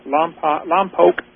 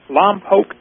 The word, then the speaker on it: Lompoc: What I got Sunday afternoon, I got to take a tour of the Diatomaceous Earth Quarry in Lompoc. I keep pronouncing it wrong. Lompoc, California.